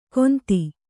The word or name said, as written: ಗೊಂದಿ